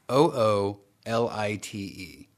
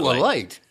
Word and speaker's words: oolite: But it's it's spelled O O L I T E. Mm-hmm. So oolite. Oolite. – Ooh, it's light. It's, it's not Ooh, light.